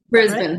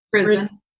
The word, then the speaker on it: Brisbane: Do I say that right? Brisbane, Australia? – Brisbane. Right? – Brisbane.